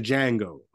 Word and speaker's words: Django: It's like it's like the Django. The D is silent.